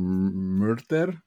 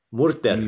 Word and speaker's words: Murter: there's an island called Murter, maybe? – Murter. – You are Murter. So, but it's spelled, but it's spelled M-U-R-T-E-R. It's very, very close to M-U-R-D-E-R.